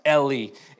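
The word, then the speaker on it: Heli: Uh, thought to be thought to be very important phrasing thought to be the son of joseph son of eli now i know it's an h but it's actually pronounced eli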